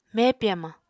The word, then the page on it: mepi hamañ: Apatani